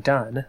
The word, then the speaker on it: Dunn: On Twitter, I am dun. That's my last name, D-U-N-N.